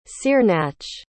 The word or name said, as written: cearnach